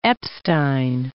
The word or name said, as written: Epstein